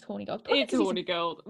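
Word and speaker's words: Hornigold: In polite society, people seem to be pronouncing it Horny Gold. Like, they kind of go fast. – I see. – It's Horny Gold.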